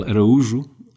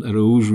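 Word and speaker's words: Araújo: So my name is Miguel Araujo, and Araujo is the Portuguese pronunciation. In Spain, they call me Araujo. When I was in France, I was Araujo. And in English, it could be Araujo or Araujo, so many different... I don't know, how do you say it in German? – I would say Araujo.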